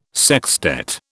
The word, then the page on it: sextet: British English